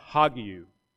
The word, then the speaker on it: hagiou: Yet I need to correct myself. Matthew says that she was with child, ek pneumatos hagiou. It's a rough breathing mark. You add the H sound, hagiou would be the correct pronunciation.